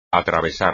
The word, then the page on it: atravesar: espagnol